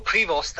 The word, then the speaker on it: Prevost: Je to možno aj tým, že, a o tom sme sa rozprávali, kardinál Prevost alebo Prevost, ako zvykol sám seba označovať alebo vyslovovať svoje meno v Amerike, bol prvý pápež zo Severnej Ameriky.